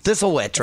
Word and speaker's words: Thistlewit: How do you pronounce it again? – Thistlewit. – Thistlewit. Mm-hmm. Thistlewit right there.